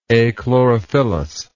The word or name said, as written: achlorophyllous